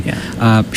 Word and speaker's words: Hemby: And Natalie, I believe it's Hemby. I want to make sure I pronounce that correctly. – Hemby, she wrote the title song, The High Women, and another song called Redesigning Women.